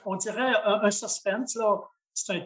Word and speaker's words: suspense: Hein, oui, mais c'est un terme qui est francisé. – D'accord, très bien. – Mais euh, on va le prononcer suspense.